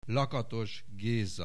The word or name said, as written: Lakatos Geza